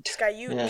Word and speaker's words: Sky Ute: Sky Ute Casino.